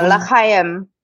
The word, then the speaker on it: L'chaim: L'chaim. Did I say it right? L'chaim. – Yeah, L'chaim.